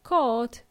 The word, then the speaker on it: caught: Spelt as C-A-T-C-H, catch, it's also an irregular verb, and the past tense is caught, pronounced caught, but spelled as C-A-U-G-H-T, caught, of course.